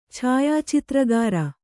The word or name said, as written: ಛಾಯಾಚಿತ್ರಗಾರ